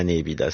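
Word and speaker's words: nepita: on dira c'est la nébida. Ça ne ressemble à rien d'autre. – Ça s'écrit Népita.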